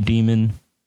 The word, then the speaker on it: daemon: Daemon. I'm going to say Daemon. Some people say demon. I say tomato. You say tomato.